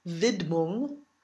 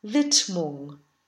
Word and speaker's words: Widmung: The D is not voiced, so it's not Widmung, but Widmung.